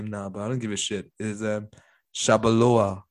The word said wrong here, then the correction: Chalobah: or what's his Chalobah. – Name? Chalobah. Chalobah. Yeah, there you go.